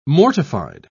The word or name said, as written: mortified